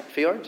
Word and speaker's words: fjords: And when I was flying over the fjords, you call them fjords? Right? Fjords, yeah? See, correct pronunciation.